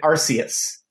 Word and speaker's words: Arceus: But I know a lot of people who pronounce it Arceus.